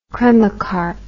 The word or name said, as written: cremocarp